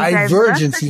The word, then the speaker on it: Divergency: Divergency, weil es von Divergency, das ist auf dem E, genau.